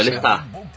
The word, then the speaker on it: Lestat: O T é mudo, gente, não se pronuncia. – É Lestat, na verdade, né? Esse que eu... Não, é Lestat.